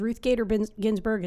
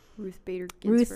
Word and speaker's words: Ruth Bader Ginsburg: It's just because it's really probably hard because Ruth Bader Ginsburg is probably... – Ruth Bader Ginsburg. – Ruth Bader Ginsburg. Yeah, I said it wrong.